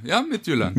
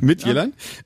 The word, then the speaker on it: Midtjylland: Sozusagen, du hast es gerade schon gesagt, Holstebro in der Region Midtjylland, habe ich ja. das richtig gesagt? – Ja, Midtjylland. – Midtjylland.